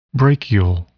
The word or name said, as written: brachial